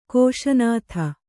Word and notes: ಕೋಶನಾಥ: kōśa nātha

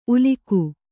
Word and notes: ಉಲಿಗೆ: ulige

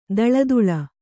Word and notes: ದಳದುಳ: daḷaduḷa

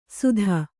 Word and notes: ಸುಧ: sudha

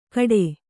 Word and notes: ಕಟೆ: kannada

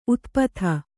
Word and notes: ಉತುಪಾತ: utupāta

